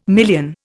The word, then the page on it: million: Indian